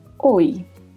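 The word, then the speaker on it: hoy: Escreve-se com a letra H no começo e no final, la Y. A letra H não tem som, e la Y é pronunciada com som de I. Oi.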